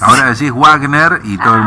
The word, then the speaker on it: Wagner: Si es una pronunciación correcta, ¿sabes cómo sería? Estoy escuchando a mi profesor de alemán, Fernando Curdín, es Wagner.